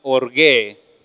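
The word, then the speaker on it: orgue (ὀργή): Una palabra en griego que se pronuncia orgue, y orgué significa castigo.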